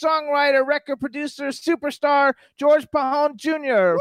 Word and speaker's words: Pajon: You said Pajon or something. – No, the J is silent. – Pajon. Okay, hold on, everybody.